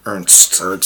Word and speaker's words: Ernst: Yeah, Ernest Jünger has a very different... – It's Ernst, right? – Ernst.